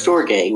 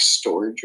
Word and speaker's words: storge: Storge, which I found out is how you're actually supposed to pronounce it. We called it like storage or something.